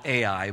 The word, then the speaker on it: Ai: I noticed that, Wes, you called it I, and you may be a little bit more privy to the Hebrew language than I am, but I've always called it Ai. But however you pronounce it, it's the same city we're talking about.